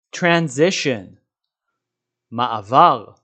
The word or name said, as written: transition